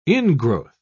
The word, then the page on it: ingrowth: British English